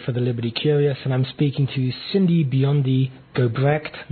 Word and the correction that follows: Gobrecht: No, I keep saying that wrong, don't I, Cindy? Gobrecht. It's the emphasis. I got to get that right.